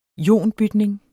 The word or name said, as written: ionbytning